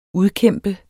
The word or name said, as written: udkæmpe